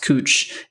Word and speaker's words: Couch: There's a very main street in Portland is spelled couch. It looks like there's just a couch street, but the one thing to make fun of out-of-towners is when they call it couch street because it's pronounced cooch.